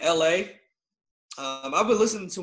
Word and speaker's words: Elhae: Um, you spell it E L H A E, but it's pronounced L A.